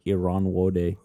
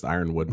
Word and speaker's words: Ironwood: I thought it was like Iran Wode. – I think it's just Ironwood.